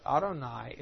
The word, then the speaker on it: Adonai: If you're reading in Hebrew and you see the word Jehovah, you never pronounce that. Orthodox Jew will never pronounce it. When you're reading the Hebrew text, when you see the word Jehovah, you say Adonai, my Lord's plural.